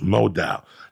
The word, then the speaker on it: modal: It's pronounced modal.